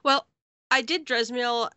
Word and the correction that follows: Zemiel: at Darkhold, Zemiel. That's how you say it.